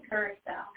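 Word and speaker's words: curaçao: blue caraco, and pineapple juice. Cheers to the blue lady. – Pronounce it curacao. – Curacao it is. Sorry, I don't know why I said caraco.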